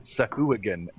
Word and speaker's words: Sahuagin: Jeremy Crawford and Chris Perkins say it's pronounced Sahuagin now. – Oh, is it Sahuagin now? – Yeah, it was Sahuagin, but they apparently...